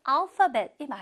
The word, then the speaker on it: alphabet: 그 알파벳이 맞아요. 발음 체크할게요. 알파벳. 알파 아니에요. 파. F 발음이 나고 있죠? 알파벳.